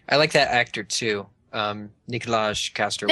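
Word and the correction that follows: Nikolaj Coster-Waldau: Nicolai Coster-Waldau. And yeah. – He's Danish. – Woo! Welcome to Sweden. – It's funny because I just butchered his name, and she just like so perfectly said his name.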